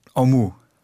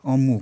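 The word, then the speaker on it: Amu: Im Persischen gibt es verschiedene A-Laute und sehr dominant ist dieser O-Laut, den es im Deutschen noch gar nicht gibt. Genau. – Okay, und die Betonung ist hinten, Omu. – Omu, genau, zweite Silbe, ist meist so in persischen Wörtern.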